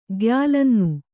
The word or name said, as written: ಗ್ಯಾಲನ್ನು